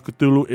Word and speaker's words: Cthulhu: And then the first syllable is not unlike the clue sound, hence the age represents the guttural thickness. So basically, according to Lovecraft, the way you pronounce the correct way to pronounce Cthulhu is you're just gonna be like it's just gaggling, gorgling sounds.